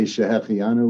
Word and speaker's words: Schehecheyanu: It's Schehecheyanu.